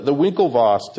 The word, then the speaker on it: Winklevoss: But the Winklevoss, did I pronounce that right? – Yeah.